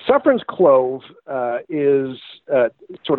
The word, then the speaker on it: Suffern: Um, most of them mispronounce the name of it. They think it's called Sovereign's clove, which is sort of ironic.